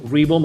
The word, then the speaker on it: Ribo: It's going to be extremely easy to find me, Angel Ribo. My last name is spelled like R-I-B as in boy, O.